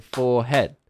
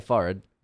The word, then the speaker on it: forehead: If you just think about it, like, you know, you say forehead, sure. That's two syllables. You know, forehead. – Yep, yep. – Say forehead, one syllable. Forehead.